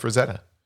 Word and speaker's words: Frazetta: Frazetta, am I saying that right? Yeah, Frank Frazetta.